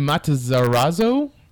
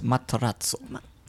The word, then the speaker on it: Matarazzo: Gaten Matarazzo. I probably butchered that. – I think it's Matarazzo.